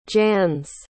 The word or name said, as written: jans